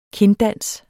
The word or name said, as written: kinddans